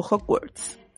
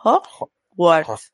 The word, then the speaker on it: Hogwarts: Sí, pero no sé por qué se dice Hogwarts cuando hay una G ahí que hay que pronunciar Hogwarts.